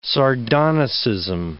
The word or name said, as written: sardonicism